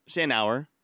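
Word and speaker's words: Shanower: Written by Anik Shanauer. Shanauer. Yeah, I said it wrong in the last episode, so I apologize.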